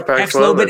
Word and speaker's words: Paxlovid: Oh yeah, Paxlovid yeah.